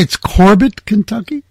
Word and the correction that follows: Corbin: Yep, Corbin, Kentucky.